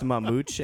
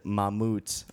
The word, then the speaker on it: Mamute: It's pronounced Mamute in Portuguese. I got a lot of shit because I said Mamute on the radio, but that's how it's spelt, M-A-M-U-T-E.